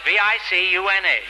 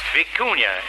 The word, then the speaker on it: vicuña: Spelled V I C U N A. Vicuña, it's pronounced. It's V I C U N A. Also pronounced vicuna because I used to have a coat that way until I hocked it.